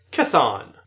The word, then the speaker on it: Chthon: Here we have Speedball saying, regarding Chiton, or I've always heard his name pronounced as Chthon, the same way that Cthulhu is pronounced.